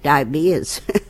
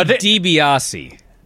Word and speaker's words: DiBiase: How do you pronounce D-I-B-I-A-S-O? – DeBase. – DeBase? DeBase. – That's how you pronounced it last week. – I would have called it diabetes. – Diabetes? It's actually DiBiase.